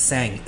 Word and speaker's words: Sang: So my Vietnamese name is Sang, S A N G.